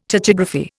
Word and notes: tachygraphy: Indian

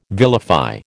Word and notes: vilify: American English

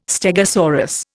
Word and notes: stegosaurus: Indian

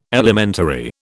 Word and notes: elementary: British English

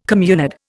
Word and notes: communard: Indian

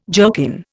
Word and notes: jerkin: Indian

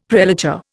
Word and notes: prelature: Indian

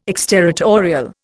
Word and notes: exterritorial: Indian

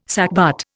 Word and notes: sackbut: Indian

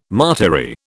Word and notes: martyry: British English

